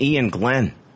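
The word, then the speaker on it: Iain Glen: Ian Glenn. I'm probably not pronouncing that right. – His name's Ian Glenn. How can you not pronounce that correct? Is it because it's spelled I A I N?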